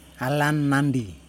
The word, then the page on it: halammandi: Yokoim